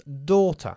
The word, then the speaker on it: daughter: Ana is Sofía's daughter. – Ana is Sofía's daughter. – Daughter. Good. Daughter se pronuncia exactamente igual que water.